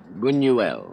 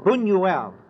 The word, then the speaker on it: Buñuel: And Zachary Scott, I'll be watching for the opening of Louis, now how do you say it, Buñuel? – Buñuel. – Buñuel. You can never tell it from the spelling.